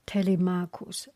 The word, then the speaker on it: Telemachus: As they were eating, Telemachus, just so that easy for you to remember, Tele, T-E-L-E, Marcus, M-A-C-H-U-S, C-H pronounced K.